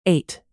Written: ate

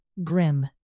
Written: grim

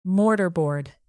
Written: mortarboard

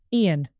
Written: ian

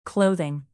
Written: clothing

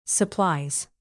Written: supplies

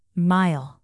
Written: mile

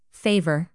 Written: favor

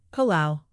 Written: palau

